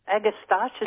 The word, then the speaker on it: Agastache: Yeah, and also Agastache, is that how you pronounce it? – Yeah.